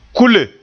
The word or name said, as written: kule